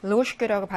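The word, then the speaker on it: 로슈케라고 (ложка): 쥐 발음 원래 음가대로 소리가 나고 아까 선생님이 1번에서 알려드렸던 숟가락은 이게 역행동화 때문에 쉐가 샤로 발음 나서 로슈케라고 발음이 나서요.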